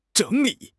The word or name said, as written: zheng3 li3